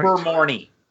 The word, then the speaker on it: Ilvermorny: Actually, I can imagine that the one they unearthed in Ilvermorny, am I mispronouncing it right? – Ilvermorny.